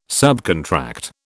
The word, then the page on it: subcontract: British English